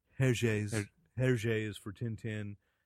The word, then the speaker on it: Tintin: And that's exactly where, you know, I think Asterisk and Dintin, or Dun Dun, as I've heard some people pronouncing it now. I can't remember. What can you think of? – You're talking about Tintin? – Yes.